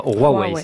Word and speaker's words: Huawei: Alors ça s'écrit Huawei, mais on doit dire Huawei, c'est ça ? – Il faut aspirer le H. – Il faut aspirer le H qui devient un R, c'est pas très logique.